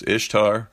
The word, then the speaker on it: Ishtar: And remember, the Queen of Heaven was this goddess of love and sex, Ishtar, Ishtar, pronounced as Easter.